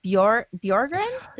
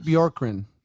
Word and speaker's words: Bjorkgren: You know, Raptors assistant, Nate, is it Bjorgren? Is that how you say his name? Yeah, I think it's Nate Bjorkgren.